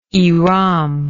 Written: ihram

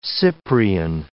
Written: cyprian

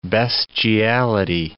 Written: bestiality

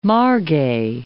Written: margay

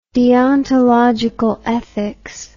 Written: deontological ethics

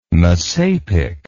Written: messapic

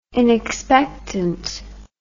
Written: inexpectant

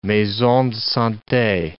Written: maison de sante

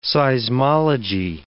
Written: seismology

